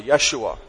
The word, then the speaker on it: Yeshua: In the Old Testament, Jesus' name is pronounced how? Does anyone remember that? – Joshua. – Joshua. Good. Joshua. Yeshua. All right, God saves.